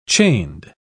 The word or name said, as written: chained